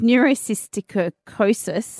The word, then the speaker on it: neurocysticercosis: It is neurocystic cirrhosis. – Oh, great pronunciation. – Neurocystic. – That sounds good. No, it's neurocysticercosis.